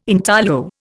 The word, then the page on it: intaglio: Indian